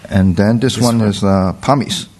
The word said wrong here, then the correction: palmier: So again, this one has the S at the end, just for plural, but so you don't pronounce it. – Okay. – So palmier.